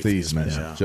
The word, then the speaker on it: Theismann: Yeah, it's actually Theismann. – Joe Theismann. – Great Golden Domer, Joe Theismann. Yeah. – They changed it to Heisman or Theismann to rhyme with Heisman, you know.